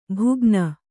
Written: ಭುಗ್ನ

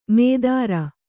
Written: ಮೇದರ

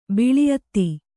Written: ಬಲ ಅತ್ತೆ

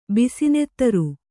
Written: ಬಿಸಿನೆತ್ತರು

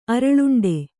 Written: ಅರಳುಂಡೆ

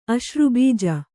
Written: ಅಶ್ರುಬೀಜ

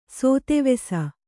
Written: ಸೋತೆವೆಸ

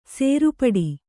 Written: ಸೇರ್ಪಡೆ

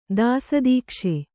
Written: ದಾಸದೀಕ್ಷೆ

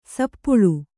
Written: ಸಪ್ಪಳು

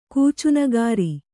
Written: ಕೂಚುನಗಾರಿ

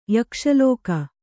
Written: ಯಕ್ಷಲೋಕ

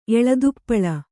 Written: ಎಳದುಪ್ಪಳ

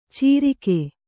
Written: ಚೀರಿಕೆ

